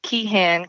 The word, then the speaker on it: Keehan: Ellen Keehan, K-E-E-H-A-N, like Nancy.